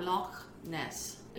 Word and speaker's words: Loch Ness: We're talking about Loch Ness.